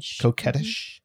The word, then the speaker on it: coquettish: how do you pronounce the word, C-O-Q-U-E? – Coquettish. – Mm-hmm. Coquettish.